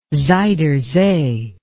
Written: zuiderzee

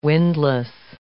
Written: windlass

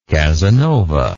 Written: casanovanic

